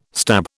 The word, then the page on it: stab: British English